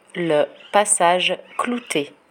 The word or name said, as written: le passage cloute